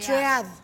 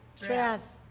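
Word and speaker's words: Thread: Acá no, está muy complicado porque se escribe como T-H-R. – e A-D. Tread. – Tread. Entonces, Así vamos a decir.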